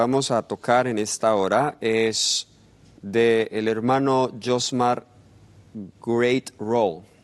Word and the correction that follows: Graterol: creo que así es que se pronuncia. – Graterol. – O Graterol.